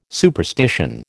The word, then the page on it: superstition: American English